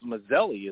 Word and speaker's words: Maselli: One of my college teachers said, well, it's one S, right? So it's Maselli is really how you pronounce it.